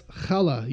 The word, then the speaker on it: Challah: Challah? Yeah, it's, it's not it's chala, you know, it's, but mm-hmm.